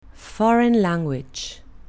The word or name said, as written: foreign language